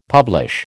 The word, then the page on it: publish: American English